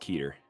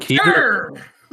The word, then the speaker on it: Keter: How do you pronounce that? I usually pronounce it Keter. – I've heard Keter! – Keter! – Y'all ever seen a Keter SCP? – Keter. – Keter. – Yeah, I've heard Keter and Keter, though.